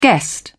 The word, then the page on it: guest: British English